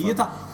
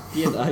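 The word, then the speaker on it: Pietà: Kim Ki-duks film Pieta, eller Pieta, eller hur man nu uttalar den här filmtiteln. – Pieta. – Det är en ständig fråga i mitt huvud. – Pieta. – Pieta, exakt.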